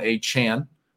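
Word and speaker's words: Achane: Devon Achan, as it's pronounced now, we learned. A lot of you sent me Adam Schefter's tweet from Monday after on our last show. I noted I had no idea how to say his name. It's Devon Achan, we learned this week.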